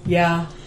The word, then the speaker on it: ye: Yeah. That's what, yeah. – They're using this word ye a lot. – No. You see, the Y is a thorn. It's actually pronounced TH.